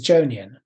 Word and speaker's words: Johnian: And it's spelt John Ian. Okay. But actually, the way you say it is Jonian.